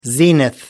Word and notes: zenith: American English

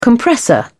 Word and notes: compressor: British English

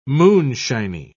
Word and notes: moonshiny: British English

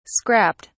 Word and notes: scrapped: British English

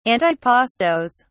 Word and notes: antipastos: British English

